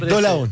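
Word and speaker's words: Dolavon: Y muy bien, pronunciaste bien el nombre de Dolabón, que no es tan fácil. – Claro, porque sí, es Dolabón.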